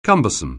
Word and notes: cumbersome: anglais

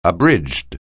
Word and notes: abridged: anglais

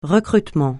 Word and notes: recrutement: francais

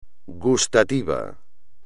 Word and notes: gustativo: espagnol

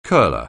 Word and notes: curler: anglais

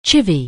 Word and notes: chiv(v): anglais

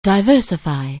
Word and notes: diversify: anglais

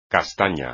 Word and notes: castaña: espagnol

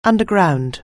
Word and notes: underground: anglais

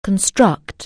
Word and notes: construct: anglais

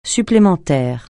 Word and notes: supplémentaire: francais

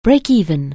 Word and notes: break-even: anglais

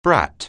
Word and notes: brat: anglais

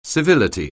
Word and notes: civility: anglais

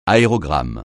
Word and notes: aérogramme: francais

